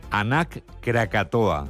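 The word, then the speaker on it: Anak Krakatau: Nos vamos a Indonesia, donde la erupción del volcán, vamos a ver si lo decimos bien, Anak Krakatoa. – Lo has dicho bien.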